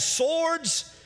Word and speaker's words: sword: There was a multitude of people, and it said they had swords, or around here we say sword, we pronounce the W, it's okay.